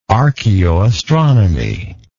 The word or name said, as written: archeoastronomical